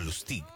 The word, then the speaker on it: Lustig: El 19 de junio de 1925, Víctor Lustig, o Lustig, vamos a pronunciarlo en francés,